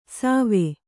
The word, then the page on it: ಸವೆ: Kannada